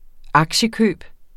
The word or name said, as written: aktiekøb